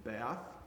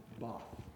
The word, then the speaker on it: Bath: B-A-T-H. We would pronounce it Bath, but Samantha Brown's been there. Samantha Brown says if you're going to be in England, you have to pronounce it Bath. Bath.